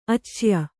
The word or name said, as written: ಆಚಯ